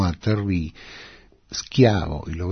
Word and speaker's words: Schiavo: Schiavo, que en Estados Unidos pronuncian Shaivo.